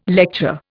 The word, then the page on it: lecture: Indian